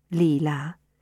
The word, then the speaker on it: Leela: Leela, whose name I pronounced the French way, Leela,